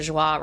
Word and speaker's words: Joie: Like for me, Joey Roberts, or Joie Roberts, however you want to pronounce it.